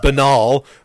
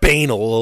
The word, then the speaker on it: banal: You know, I mean, and for people to talk about, like it said, the most banal, and I know that word should be pronounced banal, okay, but I like banal a lot better.